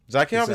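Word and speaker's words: Zakhaev: Zakayov, is that how you pronounce it? – Zakayov.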